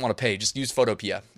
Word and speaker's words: Photopea: Someone said it's Photo P, and I'm like, well, I say Photopea, but I guess you could be right.